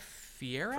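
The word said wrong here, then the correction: Ferrera: Ferreira? I thought it was Ferreira. – America Ferreira? Sure.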